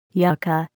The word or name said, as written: yucca